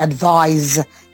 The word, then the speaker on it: advise: Now, advice and advise, A-D-V-I-C-E and A-D-V-I-S-E. The last one is pronounced like a Z sound, advise, and that this is advice.